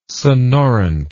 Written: sonorant

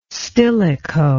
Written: stilicho

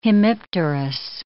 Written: hemipterous

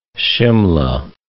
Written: Shimla

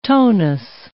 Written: tonus

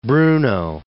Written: Bruno

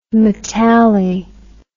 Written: metalli

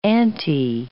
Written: anti-

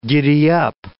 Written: giddyup

